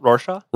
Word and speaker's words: Rorschach: How do you say that? – Rorschach? – Rorschach test? – Yeah. – Okay, there you go.